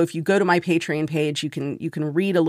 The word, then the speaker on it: Patreon: And so Patreon, it's spelled P-A-T-R-E-O-N. So it's like the word patron, but with an E in the middle.